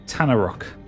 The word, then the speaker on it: Tanaruk: It is a Tanarok. – Oh, for fuck's sake. It's an Anorak. – It's spelled T-A-N-A-R-U-K.